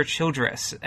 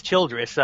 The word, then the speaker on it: Childress: So what about David Hatcher Childress? And I'm pretty sure it is Childress. At least that's how I've always heard it pronounced, except on your podcast. – I'll go with Childress.